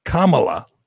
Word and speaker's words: Kamala: She's been a, a player, and I always thought it was Kam Kamala, Kamala, but it's Kamala, like Kamala.